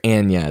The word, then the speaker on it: Anya: I mean, Anya Taylor-Joy, or Anya is how she pronounces it.